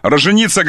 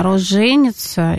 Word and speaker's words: роженица: Роженица, грамота ру. Роженица как грамот. Как правильно напиши. Боже милостивый, какие ты длинными ходами ходишь. Пожалуйста, давай. – Ну, тут два ударения. Роженица и, видимо, роженица.